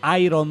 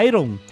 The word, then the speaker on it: Iron: Lembra do Iron Man? Que não se fala Iron, se fala Iron.